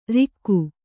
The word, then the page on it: ರಾಖಿ: kannada; rāk'hi